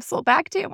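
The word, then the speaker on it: sulbactam: Solbactam, Durlobactam.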